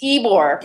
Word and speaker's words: Ybor: And it's in Ybor City or Ybor City or... – It's Ybor.